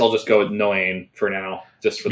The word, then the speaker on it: Noein: It's either Noein or Noin. It's from 2005. And it seems to be a coming-of-age time travel apocalypse story. – Oh. Well, that's so nice. And I'm pretty sure it's Noein. – No, okay. In terms of the Japanese pronunciation, it would be Noein.